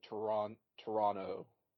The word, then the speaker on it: Toronto: Is it Toronto or Toronto? – I'm told you're supposed to pronounce it Toronto, as in there's not an extra T.